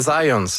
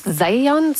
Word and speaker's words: Zajonc: c'est Zajonc. – Ouais, OK. – J'ai-tu pas dit Zajonc? – Vous avez dit plus Zajonc, mais. – Parce que j'ai prononcé un petit peu à l'anglais. J'ai prononcé mon polonais à l'anglais.